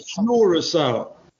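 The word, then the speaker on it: Curaçao: You say Curacao. That's what I heard. – Yeah, so it's pronounced with a K-U-R, so Curacao. – K-U-R.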